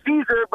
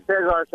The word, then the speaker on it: Caesar: No, it's Caesar, but in Spanish they call me Cesar, so it's fine both ways.